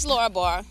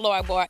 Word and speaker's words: Laura Barr: Hey guys, this is Laura Barr. We're keeping it real with Laura Barr Extended. And my name is spelled L A U R A B A R R.